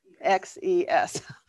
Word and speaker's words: Artaxerxes: You've got Artaxerxes. Now I'm going to probably spell his name wrong. I'm going to hopefully. – It's Artaxerxes. – Artaxerxes.